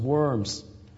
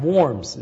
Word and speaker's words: Worms: He goes initially to Cologne, and then in the providence of God is led to Worms, which we Anglophones sometimes mispronounce as Worms. But Worms is the right pronunciation.